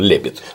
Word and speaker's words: Лепид: Извини, вот у меня с ударениями всегда плохо, я все время думал, что он лепит. – Все, конечно, правильно лепит. Да. Потому что на последний слог ударение в латинском ставится крайне редко. Обычно ставится на ударный слог. Ударный слог, он обычно не последний.